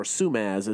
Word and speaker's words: Simaz: I mentioned at the top, we had Traverse City St. Francis wrestler Andy Simaz, or Sumaz, as his teammates call him. Because apparently nobody can pronounce Simaz, which is weird.